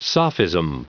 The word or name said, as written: sophism